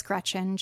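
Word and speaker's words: Greczyn: And my Instagram handle is just at Alice Gretchen.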